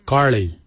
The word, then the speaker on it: giilyi: Darb is spelled D-H-A-A-B. It is a traditional knife, a darb. For boomerang, we say garli. Garli is the traditional name for boomerang. Garli is spelled G-I-I-L-Y-I, garli. For the traditional stone axe, we say gouge, gouge. Gouge is spelled G-O-O-D-J, gouge.